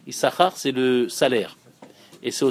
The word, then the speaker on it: Issachar: Vous savez qu'il y a deux signes dans Issachar. Un qu'on prononce, un qu'on prononce pas.